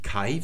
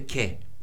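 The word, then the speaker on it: Ke (καί): Dieses AI, da spricht man E aus also sagt man nicht Kai für und wie früher, sondern man sagt heute Ke, würde man das aussprechen.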